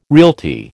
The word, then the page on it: realty: American English